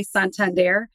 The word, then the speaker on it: Santander: Yeah, you said it the right way, but I found that whenever I do say it the right way, Santander, sometimes I have to say it three times.